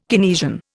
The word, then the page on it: Keynesian: Indian